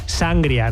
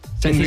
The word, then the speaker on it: sangría: Bueno, vosotros decís sangria, ¿no? Lo pronunciáis mal. – Sangría. – Decís sangria. – Sangría. – No, pero es que le ponéis ahí el acento en la A, en la primera A.